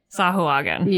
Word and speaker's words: Sahuagin: Now that I know how to pronounce it. – Sahuagin.